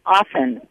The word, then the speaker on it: often: Twice I heard you pronounce the word O-F-T-E-N, often. – I always say often. What? You don't like it when the T is in there.